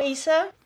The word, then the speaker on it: Isa: Hola, me llamo Isa.